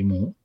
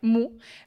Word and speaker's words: mu: la U griega pequeña esta que se dice mu, ¿no? O mu, o cómo se dice esta letra. – Eh, sí, mu. – Vale, mu, ¿vale?